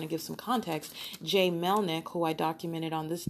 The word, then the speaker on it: Melnyk: Ontario Police Officer Jay Melnick, and that is, the last name is spelled M as in Mary, M-E-L-N-Y-K. Again, that's Jay Melnick, spelled M-E-L-N-Y-K.